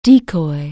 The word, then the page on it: decoy: anglais